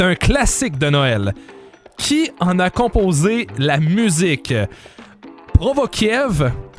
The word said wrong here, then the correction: Prokofiev: Prokofiev, mon Dieu, ça se prononce mal, ou Beethoven? C'est Prokofiev.